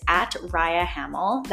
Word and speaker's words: Rhia Hamel: My username there is @RiaHamel. That's spelled R-H-I-A-H-A-M-E-L.